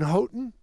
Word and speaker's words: Houghton: as well as a new partner up in Houghton.